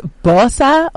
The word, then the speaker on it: BOSA: It's BOSA officially, B-O-S-A. – Yeah. – But because BOSA ya BOSA, we are not offended when people say BOSA as well. We're like, of course, it's ya BOSA and ra BOSA.